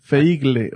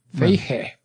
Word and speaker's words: Feige: Feigle se escribe. Feigle o Feige. – No tiene L. Feige.